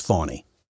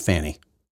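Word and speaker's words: Fani: However, okay, so all that aside, these other law cases now, of course, the Fawny, I guess her name is Fawny, not Fanny. Trump always makes a point of saying Fawny, not Fanny. It's not Fanny, it's Fawny, even though there's no U.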